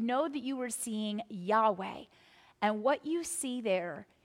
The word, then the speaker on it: Yahweh: And our next slide is going to show you that where you see Lord with the capital L and the small caps O-R-D, if you were reading in Hebrew, you would actually see the letters YHWH, and those would be pronounced Yahweh. In Hebrew, you don't put in the vowels, and so it makes it very difficult to read.